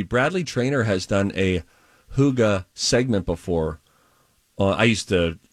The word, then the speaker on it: hygge: Hygge? – Hygge. – Hygge. – Or no. Huga? I think it's actually hygge. Yeah. Not Hugh like Hugh Jackman. Who like the who. – H-Y-G-G-E.